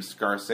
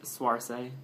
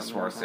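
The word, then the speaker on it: Saoirse: Scarce Ronan? However you say her name. – Swarce. – You know, like... – Who's she again? – From Hannah and, like, That one girl. – The young girl. – Oh, okay. I can never pronounce her name. But she's been, I think it's like Swarce.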